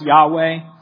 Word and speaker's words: Yahweh: But if you'll notice, the first Lord is in all caps, a reference to the Hebrew name, which has been pronounced in various ways, but most likely pronounced Yahweh.